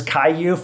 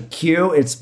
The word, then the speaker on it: Kyu: I pronounced it as Kyu for a long time. It's actually Q, but it's spelled K-Y-U.